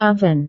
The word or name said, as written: oven